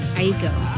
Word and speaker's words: Aiko: Aiko.